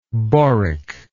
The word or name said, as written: boric